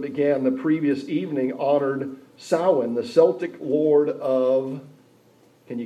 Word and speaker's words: Samhain: It's spelled Samhain, Samhain, but it's Celtic. The way the pronunciation of it is Samhain, is probably the source of the present day Halloween festival.